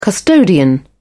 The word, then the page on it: custodian: British English